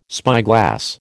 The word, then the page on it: spyglass: American English